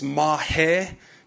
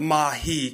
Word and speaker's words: Mahe: It's pronounced Mahe, not Mahi, because Mahi means underwear in Tongan. So please stop saying that.